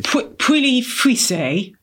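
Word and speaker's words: Pouilly-Fuissé: Well, how do you say it, just so I know that I'm saying it correctly? – Puilly Fuisse. P-O-U-I-L-L-Y. Is that right? F-U-I-S-S-E.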